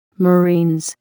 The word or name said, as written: marines